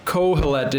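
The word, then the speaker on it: Qohelet: The Hebrew title is Kohelet, which is literally means the one who calls or gathers for the purpose of preaching. What's that? – What was the name? Title? – Kohelet. It's, if you want to spell it in English, it's Q-O-H-E-L-E-T. Q-O-H-E-L-E-T is the Hebrew word.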